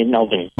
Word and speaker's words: Melbourne: You have to say it right. They say Melbourne. – Well, that's what I was doing. I was consciously leaving the R very silent there, you know.